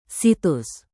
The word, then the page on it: situs: Indonesian